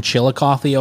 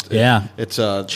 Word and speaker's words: Chillicothe: And that's how you pronounce it? I've seen the spelling. It's Chillicothe. – It ends with a T-H-E, right? – Yeah. Chillicothe. – Chillicothe.